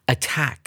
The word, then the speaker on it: attack: 首先，我们看到第一个单词 “attack”。这是一个及物动词，它的意思啊就是攻击的概念。从发音里面，我们就可以感受到那种攻击性的意味哦。 Attack，A T T A C K，重音在第二个音节。